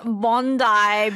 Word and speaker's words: Bondi: 본다의 비치예요. 본다이로 발음해 주세요. 호주인들은 웁니다. 저는 본다이로 했어요. – I 저는. – apologize. Bondi Beach.